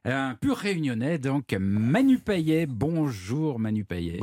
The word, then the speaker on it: Payet: Non mais c'est vrai parce que à la Réunion le T de Payet se prononce, ouais. – Figurez-vous. Et c'est ce qui fait déjà la première particularité du bonhomme que je suis, c'est-à-dire que ce n'est pas un T muet.